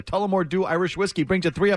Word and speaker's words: Tullamore Dew: Have I pronounced that right? I should have looked at it. – Alcohol. I like it. – Yeah, you pronounced it correctly. Tullamore Dew.